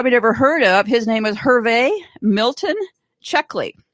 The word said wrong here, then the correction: Cleckley: Oh, Cleckley. See, I always say it wrong. C-L-E-C-K-L-E-Y. He wrote the most famous book called Mask of Sanity, okay?